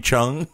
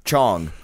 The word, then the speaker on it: Chong: Tommy Chung? – Mm. Chong.